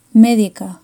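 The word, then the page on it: medica: Spanish (Spain)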